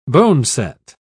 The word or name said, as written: boneset